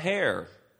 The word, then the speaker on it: heir: So if you're a child, you're also a heir. Did I say that right? Heir. Yeah, that's gonna.